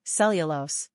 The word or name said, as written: Cellulose